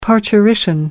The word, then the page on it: parturition: American English